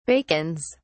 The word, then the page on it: bacons: British English